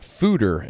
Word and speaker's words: foeder: It's tart, complex, and it's aged in old wine fodder. In an old wine fodder, F-O-E-D-E-R. What the hell is a fodder? So it's actually pronounced fooder, and a fooder is basically an oversized barrel.